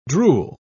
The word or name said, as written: drool